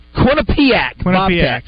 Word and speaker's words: Quinnipiac: Now, you might have to have the Quinnipiac. – Quinnipiac.